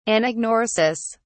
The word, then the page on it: anagnorisis: British English